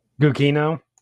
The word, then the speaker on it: Gugino: Gugino, from Sin City and a number of other great shows.